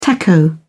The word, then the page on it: taco: British English